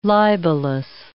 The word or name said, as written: libellous